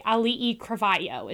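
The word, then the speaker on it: Auli'i Cravalho: So anyway, Ali'i Cravayo is how she says her name. – Okay. – So it's not too bad. No. It looks scarier than how you say it.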